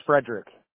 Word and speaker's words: Frederick: My full name's Frederick. – Okay, you want to spell that? – Yeah, F-R-E-D-E-R-I-C-K. – F-R-E-D-E-R-I-C-K, right? – Yeah.